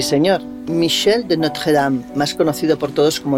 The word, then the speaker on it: Michel de Notre Dame: Mira, aprende, se pronuncia así. Michel de Notre Dame.